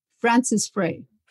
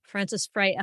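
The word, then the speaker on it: Frances Frei: Frances Frey. – Frances Frey, F-R-A-Y? – F-R-E-I, if I'm not mistaken. – I got it. F-R-E-I.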